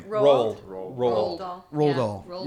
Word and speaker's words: Roald Dahl: Roald Dahl. – You know, that's one I can never. – Roald Dahl.